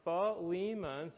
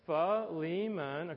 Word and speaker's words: Philemon: It's spelled a little weird, but it's actually pronounced Philemon. So you can pronounce that with me. Go ahead. Philemon.